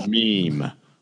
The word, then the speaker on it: meme: It's pronounced meme. Long E, silent E. Meme.